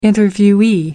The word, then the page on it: interviewee: American English